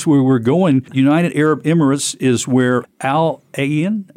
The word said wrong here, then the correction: Al Ain: saying it wrong. – Al Ain. – Al Ain. Okay. – It's a different letter in Arabic.